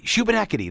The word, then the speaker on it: Shubenacadie: Shubenacadie. That's it. See, that's one of those names. I read it. It doesn't look anything like it's spelled.